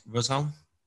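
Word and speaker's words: Rusholme: But I think it's pronounced Rushholm.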